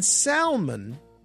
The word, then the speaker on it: salmon: The L in salmon, the name of a fish, is not pronounced. It has to do with the way the word evolved through different languages. However, there is a surname, Salmon, in which the L is pronounced.